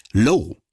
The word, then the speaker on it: Loewe: Übrigens, der deutsche Name von Loewe spricht sich in den USA etwas anders aus, nämlich Low.